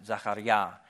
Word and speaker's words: Zechariah: In Hebrew, you'd pronounce it Zachariah.